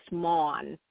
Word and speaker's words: Maughan: My last name is pronounced Maughan.